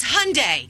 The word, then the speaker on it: Hyundai: And then we found out that Hyundai had a recall. And I said, Mom, it's Hyundai.